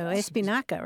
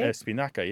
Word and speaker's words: espinaca: Espinaca, right? – Espinaca, yeah. Spanish keeps it.